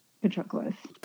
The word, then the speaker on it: Patroclus: Patroclus.